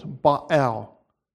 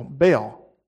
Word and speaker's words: Baal: The Hebrew way is to pronounce it in two syllables, Baal. And the Greek way is to pronounce it in one syllable, Baal.